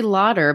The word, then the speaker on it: Lauder: Now, some people don't know that they probably call you Molly Laughter because your last name is spelled like laughter. I'm sure you get that quite a bit. – It is. It's actually Lauder, but I'll respond to laughter. So that works too.